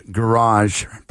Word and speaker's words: garage: Although, how do you pronounce garage? How do you pronounce garage in the UK? – Garage. – Yes! So you should say UK Garage. – UK Garage. – That's what they say.